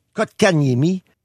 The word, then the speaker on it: Kotkaniemi: Kotkaniemi.